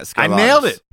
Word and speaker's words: Scarlatos: gentlemen, we've got Alec Scarlatos. It's look, pronounce it like Trebek. Trebek. – What is the exact pronunciation of the last name? – Scarlatos. – Yeah, Scarlatos. – I nailed it, dude.